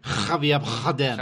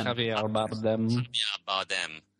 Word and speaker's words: Javier Bardem: Say it right. – Javier Bardem. – Javier Bardem.